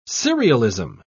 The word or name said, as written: serialism